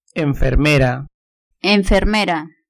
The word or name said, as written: enfermera